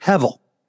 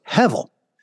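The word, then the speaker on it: hevel (הבל): It's the word hevel. Now, in the Hebrew, if you translate it over to English, it would be H-E-B-E-L, and you pronounce the B with a V. So hevel.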